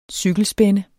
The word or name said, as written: cykelspænde